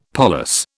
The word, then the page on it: parlous: British English